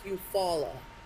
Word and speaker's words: Eufaula: Because it's actually spelled Eufaula. – Oh, gotcha. – But it's Eufaula.